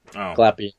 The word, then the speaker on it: Glapion: colored by Jonathan Glapion. Glapion? Glapion? How would you say That's the inker, and it's Glapion.